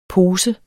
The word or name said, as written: pose